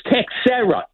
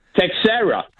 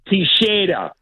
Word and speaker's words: Teixeira: Texera. And in the Portuguese, if you lived in That's Portugal, really well. They would say Teixeira. – Oh, Teixeira. – Yeah. – Yeah, but how do you say it? – How do you say it, though? – Well, I'm here in America. I would, if I see my friend, I would say Texera.